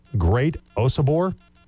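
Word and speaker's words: Great Osobor: And correct me if I'm saying his name wrong. Great Osabor. – Yeah, that's it.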